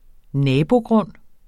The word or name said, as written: nabogrund